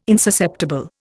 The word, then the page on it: insusceptible: Indian